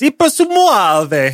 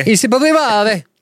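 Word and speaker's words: Eppur si muove: Actually, it's not pronounced like that because I think it's Italian. So it's Ipersumuave. – Ipersumuave.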